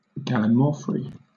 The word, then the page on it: gallimaufry: British English